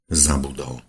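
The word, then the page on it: zabudol: Slovak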